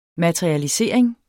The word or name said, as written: materialisering